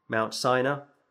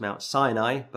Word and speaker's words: Sinai: And on top of that, you find Moses going up to Mount Sinai. Back in the Old Testament, of course, it's pronounced as Mount Sinai, but here it's pronounced as Mount Sinai.